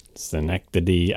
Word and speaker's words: Schenectady: Schenectady, New York, if I'm even pronouncing that correctly. – Schenectady.